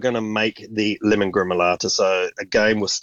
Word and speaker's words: gremolata: But could you take us through maybe the fresh pappardelle and lemon gremolata recipe? Perfect. Have I said that correctly? – Yeah, lemon gremolata.